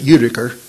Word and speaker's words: Utica: I've heard a little funny thing that makes the round, but a gospel preacher who preached in Utica, Mississippi, they pronounce it Utica, but in Utica, Mississippi, they had a congregation and they handed out a questionnaire wanting to know where all of the members were.